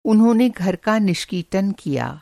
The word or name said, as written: निष्कीटन